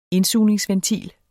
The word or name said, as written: indsugningsventil